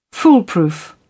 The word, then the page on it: foolproof: British English